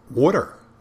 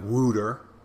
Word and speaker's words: water: W-O-O-D-E-R. When it's actually, you know, people say, well, it's water. Water. You got to pronounce the T. Water. Water. Not wooter. Wooter.